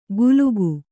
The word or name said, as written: ಕುಲುಕು